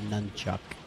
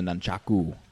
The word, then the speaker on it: nunchaku: nunchuck? I mean, you really got to get right up on there to knock anybody over. – It's actually correctly pronounced the nunchaku.